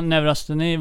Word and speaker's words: neurosteni: Bland annat så skriver du om, nu ska vi se ifall jag uttalar det här rätt ens, neurosteni, amerikanska sjukan. Ja. – Eller vad säger man? – Neurosteni brukar man säga.